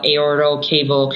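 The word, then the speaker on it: aortocaval: So almost all of us from like day one of residency, learn about aortal cable compression, which I pronounced right this time.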